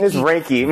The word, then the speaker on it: Reiki: I think I said that right. Reiki, right? – Mm-hmm. Reiki. It's Reiki. Mm-hmm.